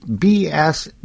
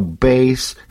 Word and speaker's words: Bass: BASS is Bass, but it's also Base. You know, how do you know how to pronounce any of the English language?